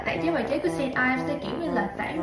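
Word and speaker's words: St. Ives: Thì hãng này đọc đúng là Saint Ives, nhưng mình thấy là nhiều bạn cũng đọc sai, nhưng cũng không sao, phải không?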